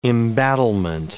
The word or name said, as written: embattlement